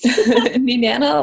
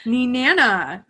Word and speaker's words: Nenana: Grew up in a small town called, can you tell me how that's pronounced? – Nenana, Alaska. – Nenana. Okay, that's how I was going to say it, but I didn't want to say it wrong.